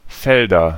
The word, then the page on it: Felder: /ˈfɛldɐ/